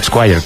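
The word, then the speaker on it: Esquire: creo que era el Squire, creo, ¿no? Donde hablaba de su adicción al alcohol. – Qué bien lo has pronunciado.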